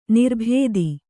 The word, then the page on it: ನಿರ್ಭೇದಿ: nirbhēdi